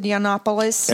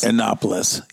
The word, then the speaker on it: Yiannopoulos: Milo, how do you pronounce it? Yiannopoulos. – Yiannopoulos. Yep.